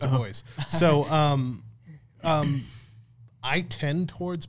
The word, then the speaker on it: Du Bois: Du Bois, which is spelled Du Bois, but everybody says Du Bois. It drives me nuts.